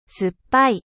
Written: 酸っぱい

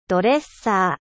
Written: coiffeuse

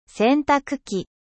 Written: lave-linge

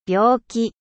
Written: malade

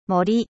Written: foret

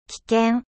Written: dangereux

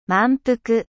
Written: 満腹

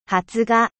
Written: germination

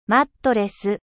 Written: matelas

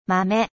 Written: 豆

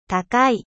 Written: cher

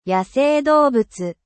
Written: faune

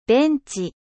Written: banc

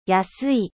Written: 安い

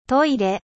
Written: トイレ

